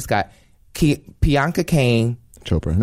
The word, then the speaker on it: Priyanka: Priyanka. – Priyanka. – Priyanka.